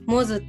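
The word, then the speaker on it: 百舌鳥: 100 下鳥って書いてモズって読む名前を少し気に入っているのでそれをラジオネームに使ってみました。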